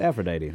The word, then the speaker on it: Aphrodite: Epaphrodite? Is that what I said? – Yeah. – Oh, it is Aphrodite. I pronounced it wrong. – Oh. – My apologies. So it's Aphrodite. That is correct.